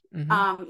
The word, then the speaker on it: Chihuahua: But then they came over through like through Chihuahua. I probably say that wrong, but through Chihuahua. – Chihuahua.